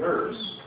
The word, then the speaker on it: herb: For they shall soon be cut down like the grass, and wither as the green herbs. They pronounce that herb over there. They pronounce it the way it's spelled.